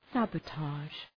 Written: sabotage

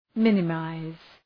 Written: minimize

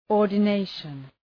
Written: ordination

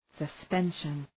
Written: suspension

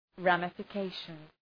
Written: ramification

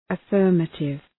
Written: affirmative

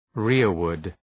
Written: rearward

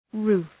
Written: roof

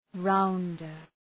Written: rounder

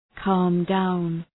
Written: calm down

